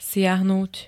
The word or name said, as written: siahnuť